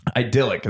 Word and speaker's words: idyllic: Idyllic is how that's pronounced.